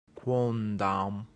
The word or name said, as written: quondam